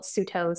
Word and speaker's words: Suto: You can find me on all my social platforms at my handle, Suto Science.